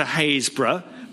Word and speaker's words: Happisburgh: An outsider to Haysborough, by the way, it's spelt Happisburg, Happisburgh, but it confuses a lot of people.